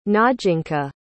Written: najinca

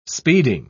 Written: speeding

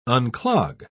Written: unclog

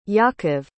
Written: yaakov

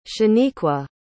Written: shanequa

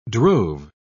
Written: drove

